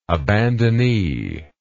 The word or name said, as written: abandonee